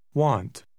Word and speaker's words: want: Want. Want. – Achten Sie darauf, dass der Buchstabe A einen ganz bestimmten Laut hat, wenn er auf den Buchstaben W folgt. Die Nummer 8 kann auf zwei unterschiedliche Weisen ausgesprochen werden.